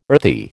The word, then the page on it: earthy: American English